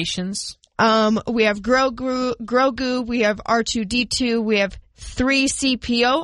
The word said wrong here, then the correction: C-3PO: I know that one. – Nope. C-3PO. – You flipped that around. I flipped it around.